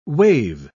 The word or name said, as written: wave